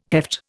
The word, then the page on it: heft: Indian